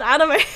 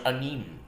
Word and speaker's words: anime: So, I want to talk about how I used to pronounce it first, because no one told me it was anime. There's no accent on the E.